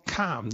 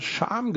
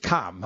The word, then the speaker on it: Cham: Jetzt wissen wir endlich mal, wie dieser Ort ausgesprochen wird, der sich ja mit C-H-A-M schreibt. Und wir Flachland-Tiroler wahrscheinlich immer Scham gesagt haben oder sowas, oder Cham oder was. Also er spricht sich Kam, haben wir schon wieder was gelernt.